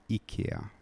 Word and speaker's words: IKEA: Can you tell our listeners how you actually pronounce IKEA? – Well, it's actually pronounced IKEA.